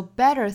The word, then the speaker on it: better: So better things can fall together. Better 重读，那 better 这里面的这个 t 呢，要读成弹舌音的 der.